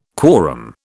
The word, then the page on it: quorum: British English